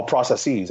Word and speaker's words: processes: All processes and communications. That is the proper way of pronouncing the plural of process.